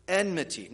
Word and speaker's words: enmity: Kids, this word is enmity. Enmity. All right. All the kids or people who think they're kids, let's try that. Enmity. It's a tricky word to say.